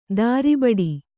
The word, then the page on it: ದಾರಿಬಿಡು: dāri biḍu